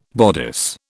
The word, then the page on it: bodice: British English